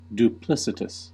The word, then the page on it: duplicitous: American English; /duːˈplɪsətəs/